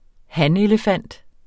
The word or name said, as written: hanelefant